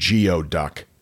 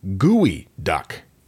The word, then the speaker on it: geoduck: The mascot is," and we said, "Geoduck." He said, "It's pronounced gooey duck." – That's not how it looks.